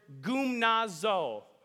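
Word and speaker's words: Gymnazo (γυμνάζω): What I love about this verse is that the Greek word that Paul uses here, the word train, is the Greek word gymnazo. Try saying that with me. Gymnazo. Now catch this, it's spelled G-Y-M-N-A-Z-O.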